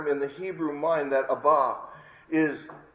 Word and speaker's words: Abba: Abba, as it's pronounced in English, Abba.